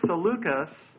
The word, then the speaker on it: Seleucus: And one of them is Seleucus. Seleucus. That's spelled S-E-L-E-U-C-U-S. Seleucus. And the second was Ptolemy. P-T-O-L-E-M-Y, Ptolemy.